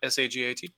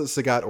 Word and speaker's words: Sagat: I liked playing as Sagat, S A G A T. – Oh, yeah, that's I think that's pretty. Uh, it, it, people pronounce it Sagat or Sagat, either way. – Okay, like Bob Saget.